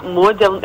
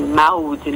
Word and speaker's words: Maude: Então, eu tenho o meu Instagram, que é @mariamode, Mode se pronuncia, né, mas se escreve MAUD, então assim, Maude, né?